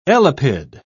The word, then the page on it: elapid: British English